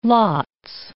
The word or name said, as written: lots